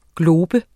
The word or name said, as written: GLOBES